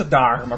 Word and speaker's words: Dharma: Anzi, peraltro questa R scritta al contrario si pronuncia anche al contrario oppure no? – Quindi si pronuncia Dharma, come direbbe il nostro Ah, sì, esatto.